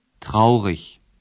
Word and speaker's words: traurig: Есть также прилагательные, оканчивающиеся на «иг», которое произносится «ich». – Traurig.